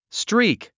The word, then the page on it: streak: stríːk; ストリーク